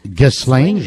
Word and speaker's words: Ghislaine: Ghislaine, je crois qu'on prononce ainsi.